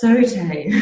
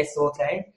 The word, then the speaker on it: sauté: So during um, jumps, which are called sautés, um, when I first started doing dance research, I kept saying saute, and all the dancers were like, no, no, no, no, it's saute.